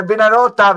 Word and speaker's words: Venarotta: Diciamo bene la pronuncia, che è Venarotta, perché la signora pronuncia Venarotta stasera ha detto 1000 volte diverso. Venarotta, ok? – Quindi Venarotta.